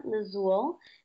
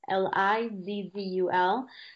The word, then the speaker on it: Lizzul: you can follow me on Instagram at Cristina Lizzul. My name is spelled without an H. It's spelled C R I S T I N A L I Z Z U L.